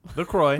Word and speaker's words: LaCroix: 3 parts pure LaCroix or LaCroix. What is it? – LaCroix. LaCroix. You said it right. – No, it's LaCroix.